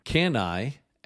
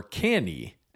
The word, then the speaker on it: Cannae: It seems that the two most accepted pronunciations are can I, as in can I borrow a pen, or canny, as in the canny fox.